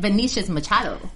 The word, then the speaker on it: Vinicius Machado: Vinicius Machado.